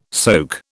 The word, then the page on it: soak: British English